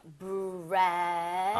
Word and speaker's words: breath: 그리고 발음도 숨 쉬다는 breathe 그리고 숨 호흡은 breath, re 이렇게 발음이 되죠. breath.